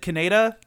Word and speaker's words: Kaneda: Kaneda? – It's like Canada.